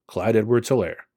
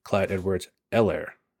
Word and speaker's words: Edwards-Helaire: Clyde Edwards-Helaire. Clyde Edwards-Helaire. I've been saying it wrong, or Helaire. I've been saying it wrong. Apparently the H is silent, which I've been calling the poor guy by the wrong name for the past, you know, 4 months.